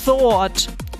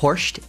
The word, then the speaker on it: Thoirt: Der Name wird T-H-O-I-R-T geschrieben. Also da denkt man ja irgendwie so, der heißt vielleicht Thord oder so. Gebt euch mal die Aussprache. – Das ist Horst.